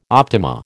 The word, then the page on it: optima: American English